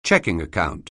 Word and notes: checking account: English

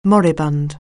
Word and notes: moribund: anglais

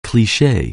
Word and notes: cleverness: anglais

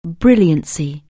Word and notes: brilliance: anglais

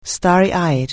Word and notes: starry-eyed: anglais

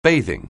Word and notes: bathing: anglais

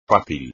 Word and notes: fácil: espagnol

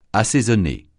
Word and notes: assaisonner: francais

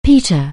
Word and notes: peter: anglais

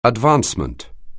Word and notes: advancement: anglais

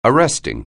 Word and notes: arresting: anglais

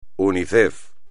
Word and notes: Unicef: espagnol

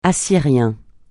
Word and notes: assyrien: francais

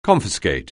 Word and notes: confiscate: anglais

